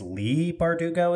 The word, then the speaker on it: Leigh Bardugo: But okay, next up, Six of Crows and Crooked Kingdom by, I think it's Leigh Bardugo is how it's supposed to be pronounced, the Six of Crows duology.